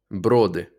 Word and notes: Броди: ˈbrɔdɪ

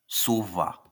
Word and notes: Suva: ˈsuβa

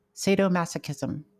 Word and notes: sadomasochism: ˌseɪdoʊˈmæsəkɪzəm; SAY-doh-MASS-ə-kiz-əm

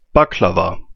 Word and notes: Baklava: /bɑːkləˈvɑː, ˈbɑːkləvɑː/

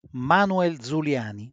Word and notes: Manuel Zuliani: [ˈmaːnwel dzuˈljaːni]; Italian

